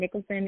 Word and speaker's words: Nicholson: You can find me at jordynn.nicholson on Instagram, and Nicholson is N I C H O L S O N um, and obviously Twitter, but I don't really get on there.